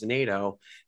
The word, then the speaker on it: Nadeau: So the spelling is T-O-U-R. And then it's my last name. My last name is Nado. So it's spelled N-A-D-E-A-U. So it's T-O-U-R-N-A-D-E-A-U.